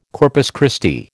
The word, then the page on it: Corpus Christi: American English